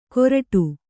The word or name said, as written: ಕೋರ್ಟು